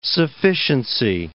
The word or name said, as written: sufficiency